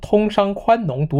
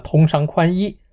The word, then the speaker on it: 宽农: 习近平 2016 年在 G20 高峰会上曾经将通商宽农读成了通商宽衣，被认为是使用简体字的后遗症。